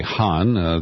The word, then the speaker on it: Khan: Khan, K-H-A-N. In Pakistan, they would say Han, the K is silent.